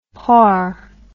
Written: porc

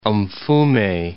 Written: Mfume

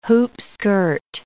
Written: hoopskirt

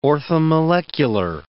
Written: orthomolecular

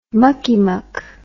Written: muckiemuck

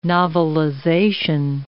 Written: novelization

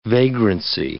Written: vagrancy

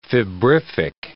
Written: febrific

